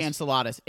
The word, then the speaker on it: Enceladus: I'm going to go into the Perseverance rover on Mars, life on Encladius. Which is definitely not how you say it. Is it Enceladus? – It's probably Enceladus.